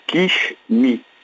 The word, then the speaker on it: Kishmi: On va écouter Kishmi, c'est bien ça? – Alors, ça s'appelle Kishmi.